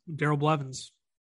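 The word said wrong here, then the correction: Bevell: Bevel. – Excuse me. Yep, you're right. Bevel.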